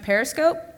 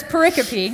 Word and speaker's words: pericope: and my boss thought I was mispronouncing periscope, and so I was like, oh, no. So anyways, that's pericope. That's how you say that, and what that is you know how your Bible is broken up, and you've got paragraphs.